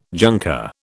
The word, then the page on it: junker: British English